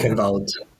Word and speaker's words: Eichenwald: Oh, Eichenwald, I don't know how you pronounce it. – Eichenwald. – German map. And Ilios, these three maps, I really love these maps, to be honest.